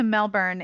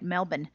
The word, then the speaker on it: Melbourne: I almost went from Sydney to Melbourne in Australia. I didn't say that right. Melbourne.